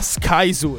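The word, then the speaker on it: Skyzoo: All'inizio ho sbagliato la pronuncia. Ho detto, ma tu sei schizo? – No. – E lui mi fa, Sky Zoo.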